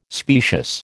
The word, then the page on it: specious: American English